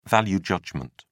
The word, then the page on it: value judgement: British English